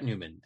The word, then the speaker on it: Nyman: Spelled N-Y-M-A-N, but pronounced Neumann. And Brett Neumann, actually, for that matter, is another.